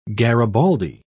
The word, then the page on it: garibaldi: British English